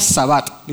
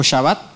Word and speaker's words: shabbat: La palabra descansó o reposó significa sabbat, literalmente, o shabbat, si lo quieren decir como lo dice la pronunciación judía.